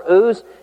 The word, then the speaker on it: Uz: The Bible says he's from the land of Uz or Uz. You can pronounce it either way you want to because people interpret Job however they want to anyway.